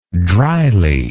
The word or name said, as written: drily